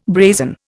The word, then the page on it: brazen: Indian